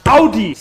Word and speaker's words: Audi: So many people say Audi when it's actually pronounced Audi. Say it with me. Audi. Very easy.